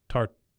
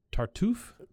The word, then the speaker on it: Tartuffe: How do you say the clown that he was? Tartuffe. – Tartuffe. – The spry wonder dog. – I think it's Tartuffe, yeah.